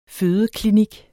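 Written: fødeklinik

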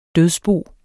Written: dødsbo

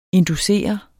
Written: inducere

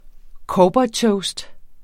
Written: cowboytoast

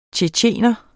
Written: tjetjener

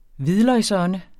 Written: hvidløgsånde

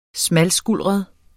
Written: smalskuldret

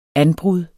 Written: anbrud

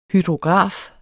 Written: hydrograf

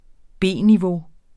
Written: B-niveau

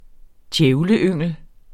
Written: djævleyngel

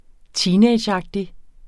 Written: teenageagtig

